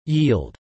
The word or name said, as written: yield